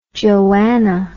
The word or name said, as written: joanna